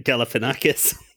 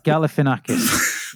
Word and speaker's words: Galifianakis: No, it's Gali is G-A-L-I, Gali. – F. Galifianakis. – Just Gali. – Galifianakis. Galifianakis. There you go. – Galifianakis. – It's, give this. – It's, there's an A. There's an A in it. Gali. It's F-I-A-N. Fian. – But he pronounces it Galifianakis. – Zach Galifianakis.